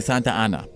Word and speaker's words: Santa Anna: In 1836, after a 13-day siege, the Alamo fell to Mexican forces led by General Antonio de López de Santa Anna.